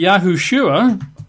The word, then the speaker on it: Yahushua: That's Y-E-S-H-U-A, or sometimes Y-apostrophe, S-H-U-A, Yeshua. Or, of course, it could be Yahshua, Y-A-H-S-H-U-A, Yahshua. But then it can also be Yahushua, extra syllable there, Y-A-H-U-S-H-U-A, Yahushua.